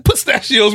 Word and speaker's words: pistachios: You said suspacio. You added two S's on it. You had an S and an I on the front of that.